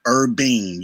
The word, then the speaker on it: Urbean: So I was about to ask you to spell it again one more time, Urbane, please. I'm sorry. – Like not urban, but Urbean. U-R-B-E-A-N Joe.